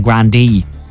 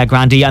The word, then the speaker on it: Grande: wasn't it Ariana Grande said everyone's pronouncing her name wrong, that it's Ariana Grande. That's how you're supposed to say it. – Ariana Grande. – Yeah, Grande.